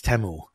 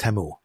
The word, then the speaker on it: Temu: Yeah, the song is like, I'm not going to sing it, but they basically say that it's Temu in the commercial.